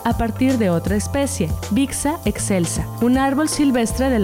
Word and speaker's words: Bixa: El nombre común del achiote se deriva del náhuatl achiotl, pero su nombre científico, Bixa Orellana, proviene del taíno Pixa, que los indígenas pronunciaban Bixa,